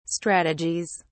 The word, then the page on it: strategies: British English